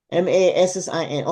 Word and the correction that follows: Nassim: Okay, Father, we just. – N as Nancy. – Huh? – N as Nancy. NASSIM.